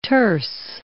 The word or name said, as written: terce